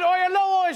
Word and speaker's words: Oyelowo: Here, because I'm the only late night host who can correctly pronounce his name on the first try, David Oyelowo is joining us, everybody.